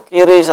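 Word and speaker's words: QRIS: Dimana jika transfer langsung tanpa melalui kris membayar admin 6.500 dan jika memakai kris gratis admin. – Berkaitan dengan kris atau kiris atau membacanya yang lainnya, semuanya boleh ya membacanya.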